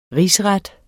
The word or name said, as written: rigsret